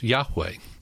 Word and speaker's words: Yahweh: And then in Exodus 6, there's one that is introduced by the Lord to Moses, and it's pronounced Yahweh. And Yahweh is, of course, the word Jehovah. Most people have heard the word Jehovah. That's kind of the English version of Yahweh.